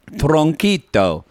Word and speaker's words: tronquito: They get the soup, and Corey's like, and they're like, and one sopa de traquillo. Or caldo what is it? I'm going to say it wrong. Tronquito. – Tronquito.